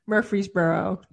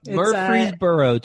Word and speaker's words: Murfreesboro: Her portfolio consists of 10 buildings totaling 900 units based in, I want to make sure I'm pronouncing it correctly. I should ask you beforehand. – Murfreesboro. – Murfreesboro, Tennessee.